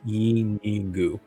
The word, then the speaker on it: Yeenoghu: Y-E-E-N-N-O-G-H-U? – Just one N. – Oh, okay. – Yeah, and it's Yenagu is how it's pronounced. – Y-E-E-N-O-G-H-U.